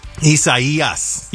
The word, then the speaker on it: Isaias: Isai, am I saying it right? – Almost. You forgot the final S at the end. – What is it? Isaias? – Isaias.